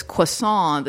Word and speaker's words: croissant: And then, you know, the one with the yellow hair, she says, that Beth Barrett's character goes, oh, it's croissant, the T is silent.